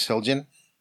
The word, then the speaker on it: Zildjian: The company itself, again, the name is Zildjian. That's divided into three syllables.